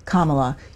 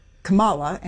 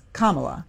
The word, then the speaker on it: Kamala: the vice president pronounces her first name Kamala. You mispronounce it. Do you do that on purpose? – No, ma'am. I don't know how she pronounces it. I've never. This is the first time anybody's ever told me that. How did I say it? – You said Kamala, and it's Kamala.